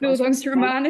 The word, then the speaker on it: Bildungsroman: I can't even pronounce that word. What's the word? – Bildungsroman, is it? – Yeah, that one, right.